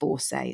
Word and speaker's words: Bourset: Tune in to Kait Bourset, and it's like no, it's just Bourset. It's say as you see.